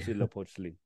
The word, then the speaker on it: Huitzilopochtli: All right, you were just talking about the Huipotli, did I say that correctly? – We'd see Lopochli. – Huitzilopochtli, Huitzilopochtli.